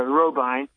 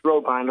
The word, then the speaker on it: Robyn: And my last name is actually pronounced Robine, like a turbine, but Robine. It looks like Robin because it's spelled R-O-B-Y-N, but the pronunciation is Robine, like a turbine.